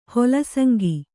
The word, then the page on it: ಹೊಲಸಂಗಿ: kannada